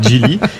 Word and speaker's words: Geely: C'est pas Geely Geely, hein. C'est Geely.